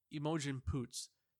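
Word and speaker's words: Imogen Poots: Emojin Poots.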